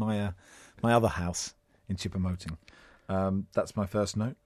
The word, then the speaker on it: Choupo-Moting: Choupo-Moting.